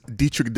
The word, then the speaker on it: Deitrich: I am your I'm your host and also one of your co-hosts, Dietrich Davis, aka, and I got it, Mark always says it wrong, but let me say it right. It is Dietrich Davis, aka Diamond Head, aka King Boomaye, the world boss.